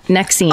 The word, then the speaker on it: NXIVM: I almost joined a cult, Nexim. – That's how you pronounce it. Nexim. – Oh, that's the branding one. – That's right. So Nexim might not sound familiar, but it's NXIVM.